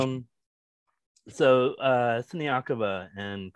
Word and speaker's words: Siniakova: If, if Krejcikova and Sineak, I'm so sorry, Siniakova.